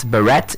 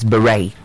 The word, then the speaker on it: beret: It's not beret, it's beret.